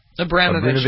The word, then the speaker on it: Abramovich: Is that how you pronounce his name? – Abramovich, yeah. Abramovich? Yeah, because he's selling Chelsea, the football club.